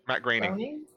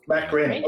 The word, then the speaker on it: Groening: Matt Groening how do you say it? – Matt Groening. – Matt Groening. Okay, wow. Really close.